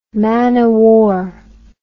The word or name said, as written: man o' war